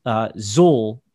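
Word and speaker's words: Xul: So I think the brewery is pronounced Zul. It's X-U-L.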